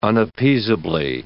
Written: unappeasably

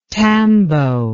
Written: tambo

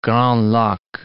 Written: Grand Lac

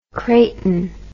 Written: creighton

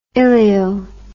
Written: ilio

